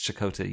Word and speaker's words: Chakotay: Chakotay. I think I mispronounced his name my entire childhood until someone corrected me and they're like, it's Chakotay, you idiot.